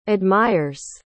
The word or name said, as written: admires